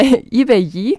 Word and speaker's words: Ibeyi: Wer genau steckt jetzt hinter diesem Namen Ibeyi? Habe ich es richtig ausgesprochen? – Du hast es richtig ausgesprochen. Hinter Ibeyi, genau, stecken die beiden Schwestern Lisa Kainde und Naomi.